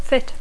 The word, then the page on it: fit: English